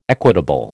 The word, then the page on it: equitable: American English